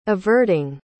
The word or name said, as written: averting